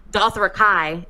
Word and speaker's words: Dothraki: Because if George told me I can pronounce Dothrakai, isn't that how he says Dothraki? He says Dothrakai. – Like Cobra Kai.